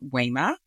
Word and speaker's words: Wema: Yes, my name is Wema, which is Swahili, and it's spelled W-E-M-A, and the E is pronounced like a long A, that language.